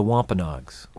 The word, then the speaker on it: Wampanoags: The Wampanoags.